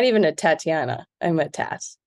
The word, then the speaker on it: Tat: Americans say Tot, which is strange to me, but I'll take it. – But that's T-O-T. You're T-A-T. That's a Tat.